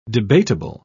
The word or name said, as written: debatable